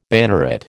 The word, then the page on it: banneret: American English